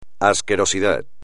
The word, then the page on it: asquerosidad: espagnol